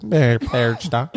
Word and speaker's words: Perchta: I think that's how it's said. But you have to say it like Perchta.